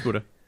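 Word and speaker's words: Budde: I think Kai Buddha or Bud. Never even knew how to pronounce his name. Do you know? – It's Buddha. – Buddha. – It is. It's Buddha.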